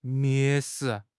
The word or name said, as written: 蔑视